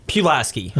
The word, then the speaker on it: Pulaski: Pulaski.